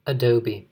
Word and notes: adobe: American English

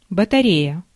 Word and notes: pile: France (Île-de-France); /pil/

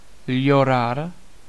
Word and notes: weep: /wiːp/

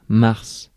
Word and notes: mars: [maʁs]; /maʁ/

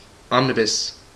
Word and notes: omnibus: /ˈɒmniˌbʌs/; /ˈɒm.nɪ.bəs/; /ˈɑːm.nɪ.bəs/

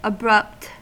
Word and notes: abrupt: American English

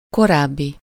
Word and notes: précédent: /pʁe.se.dɑ̃/

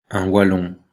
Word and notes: wallon: /wa.lɔ̃/; /valɔ̃/

